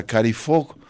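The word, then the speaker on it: Kari Faux: Ya que estábamos en el, en el hip hop y en el soul contemporáneo, vamos a presentar a Carrie Fogg Cari eh, se escribe Faux como en francés.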